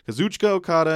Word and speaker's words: Kazuchika Okada: Kazuchika Okada. I should say it like that. Kazuchika Okada and Tomohiro Ishii versus Minoru Suzuki and Zack Sabre Jr. Nice.